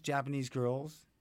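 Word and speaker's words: JPNS GRLS: Japanese Girls, pronounced Japanese Girls. Spelled J-P-N-S, girl, G-R-L-S.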